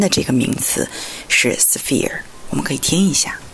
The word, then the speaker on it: sphere: s p h e r e sphere 发的时候，因为有一个 f 的音有点难。